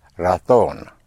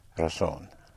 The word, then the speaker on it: razón: The word for reason, spelled R-A-Z or Z-O-N, in Castilian Spanish will be pronounced razón, otherwise razón. So it's a very, very slight pronunciation difference.